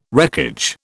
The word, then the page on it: wreckage: British English